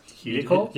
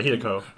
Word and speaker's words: Hideko: And the lady, Hideyoki. How do you pronounce Hideko? – Yeah, Hideko.